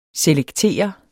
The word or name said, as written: selektere